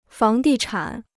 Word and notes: 房地产: fáng dì chǎn

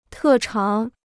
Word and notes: 特长: tè cháng

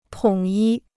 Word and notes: 统一: tǒng yī

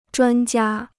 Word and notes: 专家: zhuān jiā

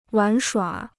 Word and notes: 玩耍: wán shuǎ

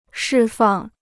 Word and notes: 释放: shì fàng

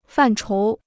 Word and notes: 范畴: fàn chóu

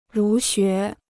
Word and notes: 儒学: rú xué

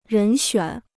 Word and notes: 人选: rén xuǎn

